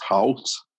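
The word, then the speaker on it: Haus: Hot Chocolate, Fanta, and a Snickerdoodle Cookie at Pinocchio's Village Hoose. Did I pronounce that right? – I think it's Halt. – Yeah, that's better. – It's German, not yep. Scottish. – Sorry. – It's Halt. It's Halt.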